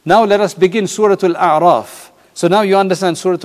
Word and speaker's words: Al-A'raf: The next one is Surah Al-A'raf. It rhymes with An'am, which is the seventh Surah of the Quran.